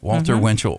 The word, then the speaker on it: Walter Winchell: Was it William Randolph Hearst, Walter Winchell, or, hey, I got the name right, Walter Winchell, or Hedda Hopper? – That's what we want to know.